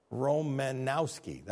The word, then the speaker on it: Romanowski: Am I saying that wrong? Romanowski. That's how we say it back in Chicago.